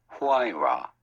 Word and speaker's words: Huayra: But he's driving his Pagani Huayra. It's only $1 million. Is that how you say it? Pagani Huayra? No, don't play. – Pagani Huayra.